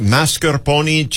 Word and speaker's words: mascarpone: Yeah, that whipped topping, it's kind of, was it mascarpone cheese? – Yes, very, very good pronunciation there.